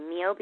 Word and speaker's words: MEOBE: One of the things that we love so much about MEOBE, is that how you say it? – Actually, that is how we say it. Make It or Break It is just a forever long name. MEOBE is a lot cleaner, easier.